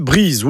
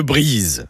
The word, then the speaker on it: Brize: Et j'en arrive donc enfin au nom de famille Brize, ou Le Brize. Alors j'insiste bien sur la prononciation de Brize. D'ailleurs, écrit avec un Z, ça éviterait peut-être d'entendre dire Brice.